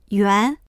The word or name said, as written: yuan2